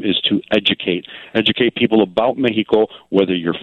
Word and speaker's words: Mexico: Mexico is the way it's pronounced, and it's the Spanish way of saying it.